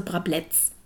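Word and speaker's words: Brablec: Mein Nachname, musst du wissen, stammt aus Polen. Und das C wird wie ein Z, wie ein Zebra ausgesprochen, also Brabletz.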